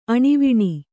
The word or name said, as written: ಆಣೆವಣಿ